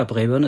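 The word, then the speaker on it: Braeburn: Ja, Brayburn ist schon richtig ausgesprochen.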